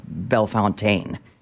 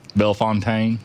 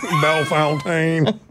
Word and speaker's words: Bellefontaine: I don't know, but it drives me crazy when someone says Bell Fountain. Tell me you're not from here without telling me you're not from here. – Bellefontaine. – Bellefontaine. Great point. How do you pronounce the name Daryl? – Oh, but that's D-A-R-R-Y-L.